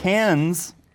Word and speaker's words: Cannes: The Cannes, I've said it correctly this time, Cannes Film Festival is happening right now.